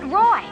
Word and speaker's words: Roi: The name Roy is obviously derived from the French word R-O-I. – Roi. That's the French word for king. But we pronounce it Roy.